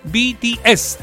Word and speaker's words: BTS: BTS.